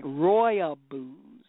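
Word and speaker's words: Rooibos: And my producer says, pronounce it right, is like Roya Booze, like Roy Rogers.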